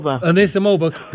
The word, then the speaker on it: Anisimova: Anisimova.